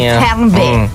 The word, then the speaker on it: Fernweh: Avendo un attimo parlato di questo prima della pubblicità, vi ho anticipato che avremmo parlato di una parola tedesca che si chiama Fernweh. – Cioè, la pronuncia è questa? – Sì. Fernweh.